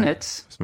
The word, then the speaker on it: Donetsk: I guess the negotiation point is going to be what happens with Crimea and the Donbass chunks, the Lugansk People's Republic and Donetsk People's Republic, if I pronounce things right. – Yeah. Donetsk.